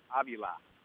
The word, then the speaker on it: Avila: You notice how I pronounce that, Beck? Avila. His son is Avila. – That was a big step that they made here, was to make that more consistent so that Al and Alex both had the same pronunciation. – So is Alex going by Avila now, or is he Avila still? Are they both Avila now? – They're both Avila now, yeah.